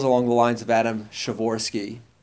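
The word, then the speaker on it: Przeworski: That's how you pronounce it, by the way. The P-R-Z-E-W is just sure, you know, there's a kind of P at the beginning that you don't actually say, but it's not just Shavorsky, it's Shavorsky.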